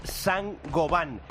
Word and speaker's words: Saint-Gobain: Bienvenidos, bienvenidos, Sangoban. – Sangoban. – Lo vas a ver escrito Saint-Gobain, porque vienen de Francia, pero se pronuncia Sangoban.